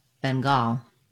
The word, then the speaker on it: Bengal: To pronounce Bengal, as in Bengal tiger, you need first syllable stress. Bang is long and gul is short. Letter N says the NG or ng sound. Bang, Bengal. Letter A is silent. Go right from the G to the L.